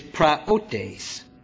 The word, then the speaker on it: praotes: Now that original Greek term, and I'll spell the word for you, is praotes. And it's spelled like this. P-R-A-O-T-E-S. I know some of you like to take notes. Let me give it to you again. P-R-A-O-T-E-S. And it's pronounced praotes.